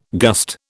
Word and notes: gust: British English

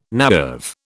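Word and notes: naïve: British English

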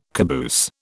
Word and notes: caboose: British English

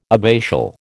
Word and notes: abbatial: American English

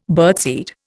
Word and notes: birdseed: Indian

